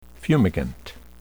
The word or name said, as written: fumigant